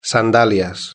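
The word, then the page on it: sandalias: Spanish (Spain)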